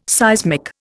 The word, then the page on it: seismic: Indian